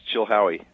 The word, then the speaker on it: Chilhowie: Chilhowie, Virginia? Did I pronounce it right? – Yes, Chilhowie.